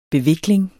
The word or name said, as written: bevikling